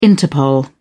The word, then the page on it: interpol: British English